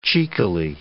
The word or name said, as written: cheekily